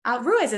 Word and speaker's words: Ruiz: Ruiz is fine.